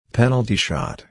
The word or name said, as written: penalty shot